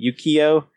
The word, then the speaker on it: Yukio: and Shinjin is fighting with Yuriko, I keep saying her name wrong, Yukio. – Yukio.